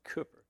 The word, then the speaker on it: Cowper: Spelled Cowper, it's pronounced Cooper.